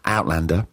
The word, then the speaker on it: Outlander: Look, you're going to know Tim from the mega smash Outlander. Is that how you say it? Outlander? Did I say Outlander? No, that's not what we emphasise. Outlander.